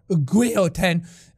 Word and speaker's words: guillotine: The diamond that led a queen to the guillotine. Or, guillotine, as the French would pronounce it.